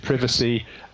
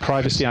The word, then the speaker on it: privacy: And I think there, you know, you highlighted privacy, or was it privacy? I can never remember how it's pronounced on both sides. Having lived in the States, I now, there's loads of words now that I just can't pronounce at all. – Both anyway. work perfectly fine.